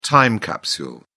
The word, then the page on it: time capsule: British English